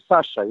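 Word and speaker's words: Saša: You know, when I explain to the people, you know, my name is Sasa. You know, Sasa. Yeah. And that is like with SH, you know, so you like pronounce Sasa.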